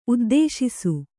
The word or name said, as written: ಉದ್ದೇಶಿಸು